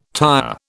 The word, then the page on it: tyre: British English